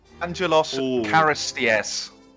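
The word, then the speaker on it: Angelos Charisteas: Angelos Karastias.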